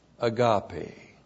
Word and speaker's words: agape: But the fruit of the Spirit is agape. A-G-A-P, long E. Agape.